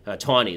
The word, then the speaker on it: Taney: Abraham Lincoln, this is also very famous, in the case called Ex Parte Merryman, there is a judge, justice, named Roger Taney, Taney is how it's pronounced, actually,